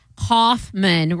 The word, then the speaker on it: Kaufman: Okay, well, it says right here, Andy, pronounced Andy, rhyming with handy, without the H, and Kaufman, pronounced Kaufman, rhyming with cough plus man.